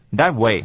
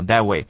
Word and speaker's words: that way: 再来是 B 段， B 段呢 Jennifer 第一句话啊，有两个简单的地方，第一个是 that way， that way 的 T 不要发音了，因为呢子音是冲突的，我们就念 that way 啊 that way。